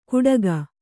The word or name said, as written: ಕುಟಕ